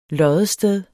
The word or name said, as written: loddested